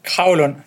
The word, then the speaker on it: Kragelund: Kragelund.